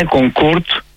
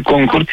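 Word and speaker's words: Concorde: Είναι Κονκόρντ και όχι Κονκόρντ για ένα λόγο. Ήταν γαλλοβρετανικό αεροπλάνο, αλλά οι Γάλλοι θέλουν να έχουν πάντα το πρώτο χέρι, το όνομα η προφορά.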